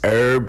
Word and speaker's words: herb: Is herb, the H is silent. – Herb, yeah. – Yeah, well, fine. That's like a regional thing. He's some people pronounce the H and some people don't.